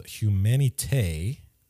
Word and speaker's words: Humanité: He's got a new album out right now called Humanité, a little accent on the E.